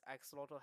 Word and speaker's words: axolotl: Okay, so they built their structures around these axolotl habitats.